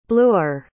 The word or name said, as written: bluer